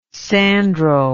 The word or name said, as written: sandro